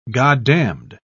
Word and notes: goddamned: British English

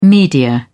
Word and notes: media: British English

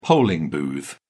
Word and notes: polling booth: British English

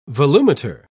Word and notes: volumeter: British English

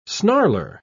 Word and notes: snarler: British English

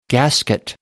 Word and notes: gasket: American English

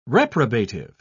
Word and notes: reprobative: British English

